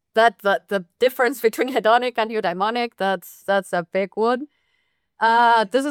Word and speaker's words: eudaimonic: In your kind of research, you talk about hedonic well-being and eudaimonic.